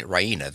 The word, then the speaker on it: Raina: She goes to the bed, expecting to find Raina there.